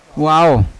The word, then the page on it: wao: Yawa